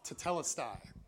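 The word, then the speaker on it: Tetelestai (τετέλεσται): Tetelestai. I think that would be the pronunciation. It's the second syllable, tetelestai. It's just one word.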